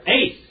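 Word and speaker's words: Ace (εἰς): Either ace or ice. – Well, ice is the German pronunciation of it. That's not the correct one. Ace is the Greek pronunciation of the Greek. Ace. Epsilon, iota, sigma.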